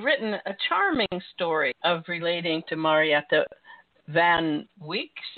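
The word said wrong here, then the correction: van Wyk: Is that how you say her name? – Van Weeck. – Van Weeck. – Marietta van Weeck would be how it's pronounced. Yeah.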